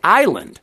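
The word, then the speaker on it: Ísland: Iceland in its original tongue is actually spelled like island with the accent over the I. So it's Island.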